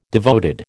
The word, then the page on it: devoted: American English